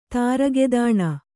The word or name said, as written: ತಾರಗೆದಾಣ